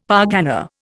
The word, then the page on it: pargana: Indian